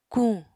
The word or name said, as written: kuñ